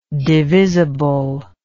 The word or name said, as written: divisibly